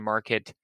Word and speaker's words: Marq: and they're bringing to market five watches in their new, I believe it's pronounced Mark line. – Sure. With a Q, so you know it's good. – That's right. Yeah. M-A-R-Q.